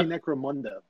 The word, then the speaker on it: Necromunda: I would say Necromunda, but yeah.